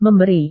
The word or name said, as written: memberi